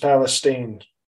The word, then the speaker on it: Palestine: It's pronounced Palestine. – Okay. – They don't want to be confused with, Middle Eastern people or Jewish people.